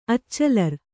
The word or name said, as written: ಅಚ್ಚಲರ್